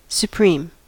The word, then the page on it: supreme: American English